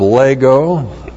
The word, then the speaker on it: lego: And then we have the verb lego. L-E-G long O, lego.